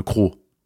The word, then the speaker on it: CRO: CRO heißt der auch nur, ne? Crow. Ohne W.